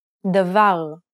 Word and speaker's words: Davar: Let's take a look at some words that are stressed on the last syllable. Try repeating so that you can get a feel for the rhythm. – Davar. Kaved. Lavan.